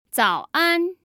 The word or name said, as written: zaoan